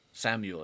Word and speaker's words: Samael: So how is it pronounced, though? – Samuel. – It is Samuel, but Samuel, with an A.